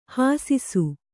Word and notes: ಹಾಸಿಸು: hāsisu

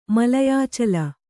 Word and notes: ಮಲಯಾಚಲ: Kannada

